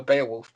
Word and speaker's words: Beowulf: I remember I went to the Beowulf premiere. The Beowulf premiere? – Say it properly. – Beowulf!